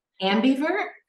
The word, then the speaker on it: ambivert: Amnivert, is that how you say it? – Ambivert? – Ambivert, right.